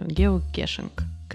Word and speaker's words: геокешинг: Кетчинг все-таки, наверное, геокетчинг. – Не, почему-то его, по-русски именно геокешинг. Геокешинг, вот. – Это, мне его кажется, называют. Какое-то неправильное произношение.